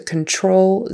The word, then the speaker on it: control: You know, on your keyboard, you might use a Control Z, control. Control. We say that TR like it's a CHR, like ch-ch-ch, troll, troll. Control Z.